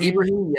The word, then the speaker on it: Ibrahim: I think it's pronounced Ibrahim, yeah.